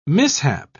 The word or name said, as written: mishap